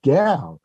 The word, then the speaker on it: Gaal: In the Apple TV Plus rendition of that same story and that same character, they take the name Gal, which is spelled by Asimov G A A L, and they pronounce it Gal, which is fine with me, and they make it a woman character.